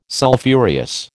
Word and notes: Sulphureous: American English

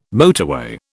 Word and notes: motorway: British English

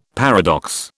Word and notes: paradox: British English

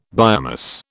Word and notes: biomass: British English

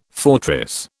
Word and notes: fortress: British English

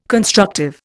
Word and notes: constructive: Indian

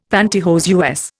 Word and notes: panty hose: Indian; American English

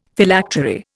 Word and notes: Phylactery: Indian

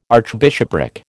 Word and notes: archbishopric: American English

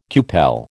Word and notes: cupel: American English

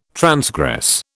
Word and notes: transgress: British English

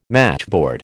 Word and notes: matchboard: American English

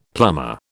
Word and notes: plumber: British English

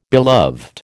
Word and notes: beloved: American English

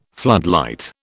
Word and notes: floodlight: British English

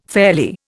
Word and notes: fairly: Indian

